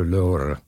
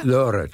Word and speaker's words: Lorre: You Americans pronounce it Lorre. – Yes, we Americans. – It is Peter Lorre. – Peter Lorre. – Lorre? – Lorre, two Lorre. Syllables.